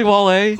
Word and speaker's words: Wale: Is it really Wale? – Yes. – Like out of Wale, I mean, it is spelled like whale, Grody. It is spelled like whale. – W-H-A-L-E. – You are not wrong. – No, it's W-A-L-E. – There's no H.